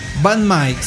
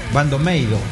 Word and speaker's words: BAND-MAID: Van Might, se pronuncia en japonés Bandomeido. Bandomeido. Van Mai sí, se pronuncia Bandomeido.